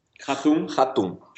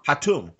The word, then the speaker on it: Hatoum: Hatoum. – Hatoum.